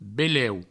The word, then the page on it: beleu: Provençal